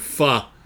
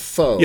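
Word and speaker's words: phở: Which is basically, it assumes people are misreading the word fuh. – Because it's spelled P-H-O, and so Americans it looks like pho. – Yeah, Americans will probably assume that means pho, like phone, but it's actually pronounced fuh.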